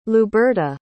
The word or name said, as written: luberta